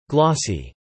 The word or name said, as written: glossy